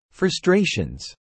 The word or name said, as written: frustrations